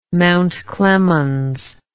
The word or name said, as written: Mount Clemens